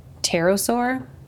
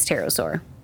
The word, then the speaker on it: pterosaur: I was like, God damn it, I can't remember the dinosaur's name because it has a P, but the P is silent, so I'm assuming it's pterosaur.